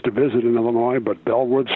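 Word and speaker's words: Beall Woods: And if you're looking for information on Bellwoods State Park, the pronunciation, B-E-A-L, L looks like Beale, and everybody who is unfamiliar with the site probably says Beale Woods State Park. It's actually pronounced Bell Woods State Park, as if it was B-E-L-L.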